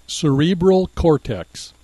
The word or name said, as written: cerebral cortex